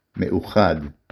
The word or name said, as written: אחד